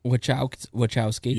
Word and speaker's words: Wojcicki: How do you say her name? – Susan hates winning. – Wojcicki.